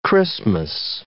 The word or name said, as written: Xmas